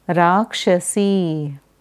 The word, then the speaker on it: Rakshasi: Rakshasa, Rakshasi.